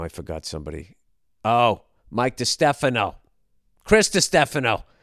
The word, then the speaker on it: DiStefano: Chris DiStefano. There, I finally said it right.